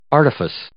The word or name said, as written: artifice